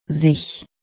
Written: sich

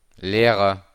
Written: Lehrer

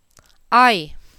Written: Ei

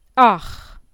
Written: ach

